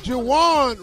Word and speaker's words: Juwan: Let's talk about Juwan Howard. – Howard. – Yeah, man. – Juwan rhymes with Deshaun.